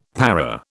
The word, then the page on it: para: British English